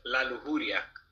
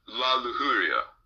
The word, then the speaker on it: La Lujuria: It's in Spanish, it's La Lujuria. – La Lujuria.